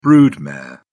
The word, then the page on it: brood mare: British English